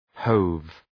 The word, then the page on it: hove: həʋv